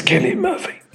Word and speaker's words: Cillian Murphy: It's Cillian Murphy. – Absolutely. – It's not Cillian. Cillian Murphy. Cillian Murphy. – It is Cillian. Absolutely. Not Cillian.